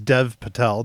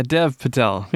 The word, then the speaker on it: Dev Patel: Almost as bad as Dev Patel, not Dave Patel, but it's okay. – Oh yeah, Dev Patel.